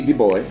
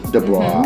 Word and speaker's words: Du Bois: Du Bois, right? – Dubois. – Mm-hmm. Mm-hmm. Yes. Mm-hmm. But you know, a lot of people say Dubois. – I know they do. – They do. – They mispronounce it.